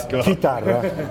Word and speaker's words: chitarra: It's chitarra. It means guitar.